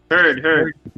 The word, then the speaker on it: Heard: Este, pues nada, Johnny Depp y, y, Hart, como se llama el apellido? Siempre lo pronuncio mal. – Amber, Hart. – Heard. Eso mismo.